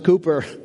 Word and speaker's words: Cowper: As the beloved William Cowper hymn, spelled Cowper, pronounced Cooper, William Cowper hymn, behind a frowning providence, he hides a smiling face.